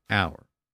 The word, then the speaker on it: hour: And that's why the word hour has that silent H at the beginning, because it was originally pronounced by the Greeks in the original Greek version of the word.